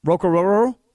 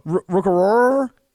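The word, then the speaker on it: Orhorhoro: Rukaroro? – Rukaroro. – Rukaroro? – Aroro. – Rukaroro. No, I didn't know that's how you said it. – Yeah, that's how you say it.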